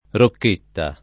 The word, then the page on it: Rocchetta: rokk%tta